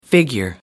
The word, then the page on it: figure: American English